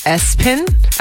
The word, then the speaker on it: ESPN: This person's name was ESPN, pronounced Espen.